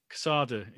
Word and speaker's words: Quesada: Is it Quesada? Quesada? – Quesada, yeah. Well, I pronounce it Quesada. Sorry if I'm wrong, Joe.